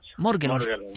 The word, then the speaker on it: Morgellons: ¿Cómo se dice esto, realmente? Morgelons o morgellons? Morgellons.